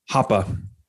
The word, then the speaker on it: Hoppe: Hoppe.